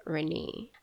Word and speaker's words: Renna: I did not change the spelling because mommy spells it R-E-N-N-A, but she pronounces it Renee. And I was teased throughout the years. I was called so many names, bad spelling Renee. The mispronunciations just went on and on and on and on. And one of the most prominent ones was Renna. So in 2014, when I went to open my first bank account, the customer service rep, once again, of course, mispronounced it.